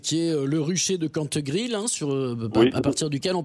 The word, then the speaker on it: Cantegrille: C'est intitulé Le Rucher de Cantegrille, donc tout attaché, Le Rucher, comme ça c'est écrit, de Cantegrille, comme ça se prononce, C-A-N-T-E-G-R-I-L.